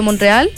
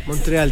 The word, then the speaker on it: Montreal: Es que no tiene E. Se escribe Montreal. – Ya, pues, Montreal. Y si dicen Montreal, es como que le agregan una E. Que no sé, yo digo Montreal.